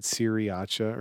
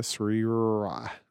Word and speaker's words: sriracha: I think I said "siriacha" or something, and that's not it. "Sriacha," sriracha.